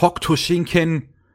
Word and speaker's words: Hokuto Shinken: Beziehungsweise, das ist ja die, die, die, die Kampffähigkeit des Protagonisten und seine Brüder, die Shinken, ho, ho, das Hokuto Shinken, ja. oder wie es, wie es im Deutschen immer ausgesprochen wurde, Hokto Shinken.